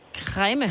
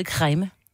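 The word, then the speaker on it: Kregme: Jeg bor kræme. – Ja. Kræme. – Kræme. Den var god.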